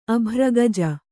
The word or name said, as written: ಅಭ್ರಗಜ